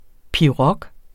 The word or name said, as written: pirog